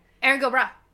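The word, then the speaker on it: Erin go bragh: And, from two Irish girls, Erin Gobrady. I think it's how you say it? – Yeah. – I said it right. – Erin Gobra. – Erin Gobra. There we go.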